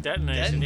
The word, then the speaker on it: detonation: What's denotation? – Detonation. Yeah, that's what I was going to say. – You put the emphasis on the wrong syllable.